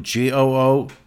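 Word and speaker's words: Goo Hara: That is not one name. That is two. Goohara. And if I remember with the Koreans... the family name is pronounced first, so her Americanized name would be Haragu.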